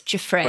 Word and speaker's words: Giuffre: Virginia Roberts Giuffre.